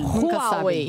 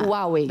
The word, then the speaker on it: Huawei: Huawei. – Tá. – Huawei.